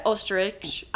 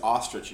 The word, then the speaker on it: Oesterreich: Fred Oesterreich, I'm probably pronouncing that wrong. – Yeah, I believe it's ostrich and you're trying to be polite.